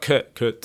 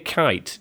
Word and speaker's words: Kuyt: It's not Kuyt then. – No. – It's not Kurt. – It's Dirk Kuyt. Dirk Kuyt. – When we said Kuyt when he first signed.